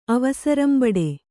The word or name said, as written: ಅವಸರಂಬಡೆ